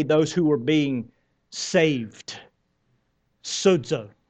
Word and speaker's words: sozo: The word is sozo. Sozo. You've heard me talk about it before. Sozo. Some people spell it S-O-Z-O, I think, in the Greek.